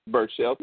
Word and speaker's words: Berchelt: Yeah, I'm not really, you know, too interested in that, but I have seen Berchelt. How do you say that? How would you say that? – I believe you said it right there. – Berchelt.